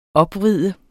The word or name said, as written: opvride